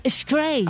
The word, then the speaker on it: Skrei: Se dice así, del Skrei. – Ah, bien dicho.